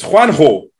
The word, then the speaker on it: Juanjo: Why did we pronounce the J as a wh and then the other J as a j? It's Juanjo. But someone said Juanjo and it's stuck forever. It's never been Juanjo in his life.